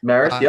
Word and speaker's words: Marist: Marist, yep, you got it.